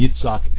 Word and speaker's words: Yitzhak: I change those back to Avraham, Yitzhak, and Yaakov whenever I remember to, which is most of the time. I like to actually use the Hebraic pronunciation of those names.